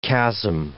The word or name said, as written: chasm